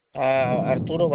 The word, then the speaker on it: Arturo: Arturo Barajas.